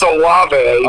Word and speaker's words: Olave: And then, Devontae Smith versus Chris Olive, or Ali, however the hell you pronounce his name. Olive. I would assume he likes. – I think it's Olave.